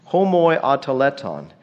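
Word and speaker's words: homoioteleuton: Homoiotelēton, that's a mouthful, that's six syllables.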